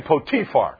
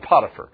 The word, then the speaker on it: Potiphar: And remember, you pronounce his name Potiphar. We just call him Potiphar. It's easier to say that, but his name really in the Hebrew pronunciation is Potiphar.